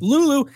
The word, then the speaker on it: Lulu: So the song, The Man with the Golden Gun, was sang by Lulu. I hope I said it right. – It is Lulu. Yeah, I thought it was Lulu.